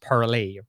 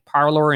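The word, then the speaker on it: Parler: According to the founders who, who launched this campaign, sorry, this platform back in 2018, uh, the intention was, was for it to be pronounced parlay, right, as if it was the French pronunciation. But as it got, it became, it, it started to become popular here in the U.S. It, you know, uh, uh, people started calling it parlor, and now people are using that more as the actual name of the platform.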